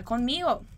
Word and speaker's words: conmigo: E daí, ele diz, vale, cuenta conmigo. Cuenta conmigo. Muito cuidado com essa pronúncia, tá? Você tem que fazer esse Nzinho. Conmigo.